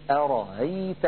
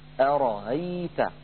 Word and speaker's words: أرأيت: فإن سهل همزة مفتوحة كالمثال الذي معنا هنا ينطقها بين الهمزة المحققة والألف هكذا أرأيت.